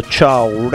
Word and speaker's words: Chow: all'indirizzo Ciao Ragaz, che è scritto C-H-O-W Ragaz, proprio come si pronuncia.